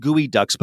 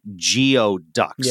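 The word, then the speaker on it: geoduck: Uh, gooey ducks are, and I say it's pronounced gooey ducks, but it looks like geo ducks, yeah.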